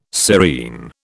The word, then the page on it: serene: British English